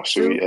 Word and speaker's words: Sui: Stewie? – Oh, Stewie, S-U-I. – The S-U-I, yeah.